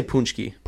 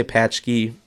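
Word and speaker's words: pączki: We say punchki. – Punchki. – Yeah, some people say patchki.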